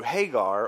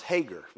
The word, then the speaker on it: Hagar: Maybe it would be through Hagar, or as from the south we pronounce Hager, you know.